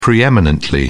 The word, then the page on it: pre-eminently: British English